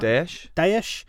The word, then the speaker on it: Daesh: Daesh.